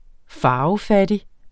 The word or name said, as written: farvefattig